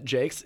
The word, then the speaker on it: Jacques: I will start off by saying that I didn't used to pronounce it Jakes. It's spelt like Jacques or Jocks. And it wasn't until this year when I was a couple of months ago when I was listening to the audiobook by Brian Jakes himself, where he pronounced it at the start of the audiobook as Brian Jakes.